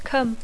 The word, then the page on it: come: English